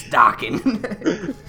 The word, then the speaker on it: Dokken: The rest of the night is probably going to be like, Dokken. – Who the fuck is Dokken? – It's Dokken.